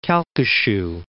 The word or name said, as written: Calcasieu